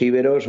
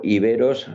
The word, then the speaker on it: iberos: que se aceptan las dos pronunciaciones, aunque si realmente lo traducimos del griego, iberos sería más correcta que íberos, aunque se ha popularizado más la palabra íberos que iberos.